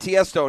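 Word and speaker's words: Tiësto: Tiesto will perform – I had Tiesto in his proper pronunciation.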